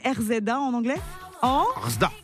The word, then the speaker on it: RZA: RZA.